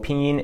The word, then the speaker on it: Pinyin: So Pinyin is pronounced in a double first tone. So that's spelled P-I-N-Y-I-N.